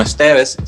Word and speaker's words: ZTVZ: Sí, pues nos puedes conseguir en las redes sociales como Esteves, se escribe ZTVZ, es básicamente nuestro apellido sin las vocales.